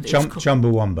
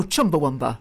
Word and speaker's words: Chumbawamba: This is, I don't know how to pronounce this, it's Chumbawamba. And Chumbawamba. – Oh, Chumbawamba.